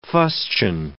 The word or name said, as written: fustian